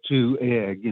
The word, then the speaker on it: Two Egg: It's spelled T-W-O-E-G-G. It literally is a community named Two Egg, just as if you looked in your refrigerator and you had two eggs left. It is spelled Two Egg.